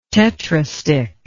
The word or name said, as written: tetrastich